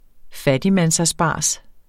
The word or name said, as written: fattigmandsasparges